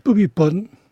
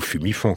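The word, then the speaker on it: Bhumibol: Pumipon. – C'est pas du tout Phumifon, comme dit... – Non, c'est pas Phumifon.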